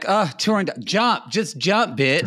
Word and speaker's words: Turandot: If you go into a gay man's house and they have that poster of it's called, you're going to say Turandot, but it's pronounced Turandot.